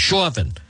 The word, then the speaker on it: Chauvin: If convicted of second degree murder, Chauvin, the police officer, I sound like I'm, I, when you say his name, it sounds like it's a Boston accent. Chauvin. It's the store to him. Chauvin would likely face, that's just the way it's pronounced, Chauvin would likely face about 11 to 15 years in prison.